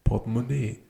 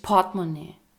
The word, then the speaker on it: Portemonnaie: Portemonnaie. Für mich gibt es nur noch iClip und... – Ja, für mich ist die Betonung halt hinten. – Ja. – Nicht Portemonnaie. Portemonnaie.